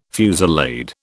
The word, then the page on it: fusillade: British English